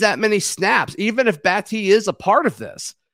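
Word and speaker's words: Battie: And it's interesting because there was another running back that came in, Brian Battee. It's not Batty. Sorry, I've been saying his name wrong. Brian Battee, the South Florida back.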